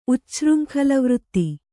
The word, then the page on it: ಉಚ್ಛೃಂಖಲವೃತ್ತಿ: Kannada